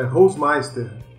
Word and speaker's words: Holzmeister: É Holzmeister mesmo que fala, André? Ou é diferente a pronúncia? – Cara, é quase isso, é Holzmeister.